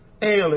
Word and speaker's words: El: E-L, El, El. It's a long E in Hebrew, El.